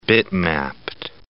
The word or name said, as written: bitmapped